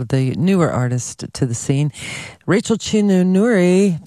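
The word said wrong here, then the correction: Chinouriri: if I can say it right, Rachel Chinuriri, there we go.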